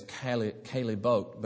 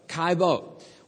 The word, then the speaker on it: Caillebotte: I had to look it up how to pronounce it, because I've always thought of it as Caillebotte, but you don't pronounce the L's. Anyway, so Caillebotte.